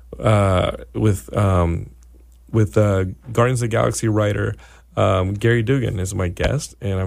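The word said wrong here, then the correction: Gerry: I'm really excited to talk to him about it's Jerry. It's spelled like Gary, but it's Jerry.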